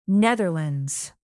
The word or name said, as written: netherlands